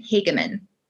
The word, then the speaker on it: Hagemann: It's a long A. Hagemann.